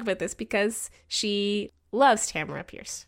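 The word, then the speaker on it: Tamora Pierce: Okay, I'm going to rewind you a bit. Tamara Pierce. – Yeah. Did I say Tormora? – Yes, you did. Said Tormora Price, not her name.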